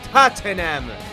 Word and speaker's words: Tottenham: Se dice así, Tottenham.